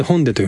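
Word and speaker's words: ホ ン デ (홍대): 韓国では 7 店舗目となるアップルホンデ。漢字では工房大師の工という字に大きいと書くんですけども、これでホンデと読みますね。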